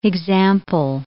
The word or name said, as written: example